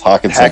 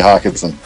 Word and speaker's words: Hockenson: Is it Hokus something or other? – It's Hockenson. DJ Hockenson.